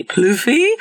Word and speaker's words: Plouffe: But I am bringing you A Citizen's Guide to Beating Donald Trump by David Plouffe, P-L-O-U-F-F-E. Plouffe? I don't know how to say his name. – Plouffe.